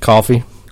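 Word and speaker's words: Coffey: Coffee. Not spelled the same.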